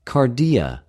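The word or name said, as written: καρδια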